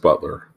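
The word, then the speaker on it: Beutler: and a statement released by Congressman Jamie Herrera Butler, not sure how to pronounce her name. – I think it's pronounced Butler.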